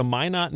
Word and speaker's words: Minot: And I can tell you know it because you said Minot correctly. Not a lot of people say that correctly.